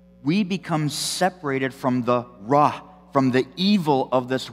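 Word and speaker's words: ra (רע): The Hebrew word for evil is pronounced ra, and the Hebrew word for good is pronounced tov.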